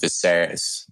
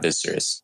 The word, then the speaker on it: Viserys: And to correct myself from earlier, it's pronounced Viserys, not Viserys.